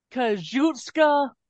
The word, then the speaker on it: Kazuchika: Kazuchika Okada.